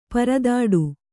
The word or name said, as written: ಪರದಾಡು